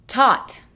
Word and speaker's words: taught: Taught. Though. Fought. Through. Throughout. Thorough. Tough. Para empezar tenemos taught. Ese sonido ah es justo entre la A y la O.